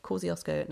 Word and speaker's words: Kosciuszko: He's native to the southern tablelands of Australia and only found in the snowy mountain region of Kosciuszko National Park. I think I said that right. It's a Polish spelling. So Kosciuszko National Park.